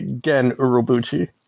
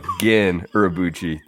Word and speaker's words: Gen Urobuchi: And I really think that helped me know what to expect from Jin Urobuchi, if I pronounce that right. – I'm going to have to be the nerd here and say Gen Urobuchi. – Thank you. – There we go. Gen Urobuchi.